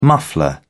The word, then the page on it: muffler: British English